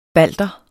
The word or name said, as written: balter